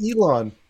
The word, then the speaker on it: Elon: Oh, no, it's Elon.